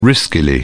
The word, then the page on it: riskily: British English